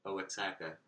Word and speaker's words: Oaxaca: and it's spelled OAXACA, Oaxaca, but it's pronounced Oaxaca.